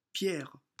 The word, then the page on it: Pierre: French